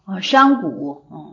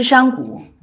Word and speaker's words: 商贾: 商贾。我刚才读成商贾了吗？是商贾，没注意，这个是普通的，一般的情况下都应该知道的。我刚才读成商贾了，好的，泛指商人，商贾是对，我刚才是读的是商贾啊，商贾就是说他指的是商人，读的应该是读商贾。